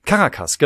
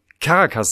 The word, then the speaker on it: Caracas: Caracas, genau. Ich dachte immer, die Hauptstadt von, oh Gott, Venezuela, ne? Ja, ist äh, Caracas, nicht Caracas, genau. Aber richtig ausgesprochen ist es offenbar Caracas.